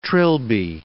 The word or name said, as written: trilby